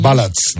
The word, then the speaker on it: ballad: Power, ¿cómo se dice esto en inglés? – Ballads, ¿no? Balas. – No, que balas. Es de balada. – Claro, balas. – Power balas. – Baladas, balad. – Ballad, muy bien.